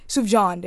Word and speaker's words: Sufjan: Sufjan Stevens, did I pronounce that right? I hope Sufjan. – I did. Sufjan, there we go.